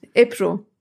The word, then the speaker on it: Ebru: Und ich habe mich aber so dran gewöhnt, weil keine Lust jedes Mal dann doch zu erklären, wie ich heiße, wenn ich meinen Namen halt türkisch ausspreche. Deswegen stelle ich mich immer als Ebru vor. Das ist halt so richtig Standard geworden. Das heißt, jeder aus meiner Umgebung, abgesehen von den Türken, denken halt, ich heiße Ebru, obwohl es halt, an, halt anders ausgesprochen wird.